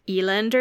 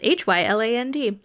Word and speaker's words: Hyland: Veronique has a very French first name, so I really hope her last name is not pronounced Eland or something, but it's Hyland.